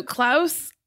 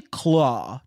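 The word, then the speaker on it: Klaue: So Klaus or Clow? Clow? – It's actually Claw, just like LOL. – It's spelled like a stupid way,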